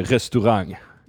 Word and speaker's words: restaurang: So we say restaurang. – Yeah. – And it ends with ng, which signifies this ng sound at the end.